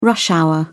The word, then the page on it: rush hour: British English